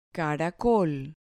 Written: caracol

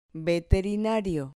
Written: veterinario

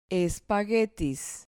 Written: espaguetis

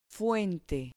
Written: fuente

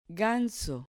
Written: ganso

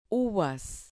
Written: uvas

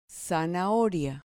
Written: zanahoria

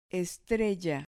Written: estrella

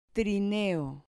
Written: trineo

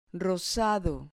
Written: rosado

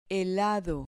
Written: helado

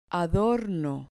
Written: adorno

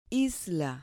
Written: isla

